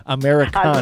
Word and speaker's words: Americana: Y también nosotros decimos en inglés, hay como un género de música o subgénero en la música country que se llama Americana. Así se dice. Se dice así a lo gringo. Americana.